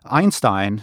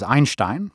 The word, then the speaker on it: Einstein: Einstein is how Americans say the name. The correct pronunciation is Einstein, but if I said that, no one would know what I was talking about. If I didn't narrate this myself, my dream narrator would be Helen Mirren.